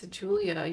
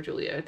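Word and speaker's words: Giulia: It's Julia. You have to remember Julia. They say Guglia? That 100 times. – Julia? Julia Guglia? – I like her name. It's spelled with a G. G-I-U-L-I-A.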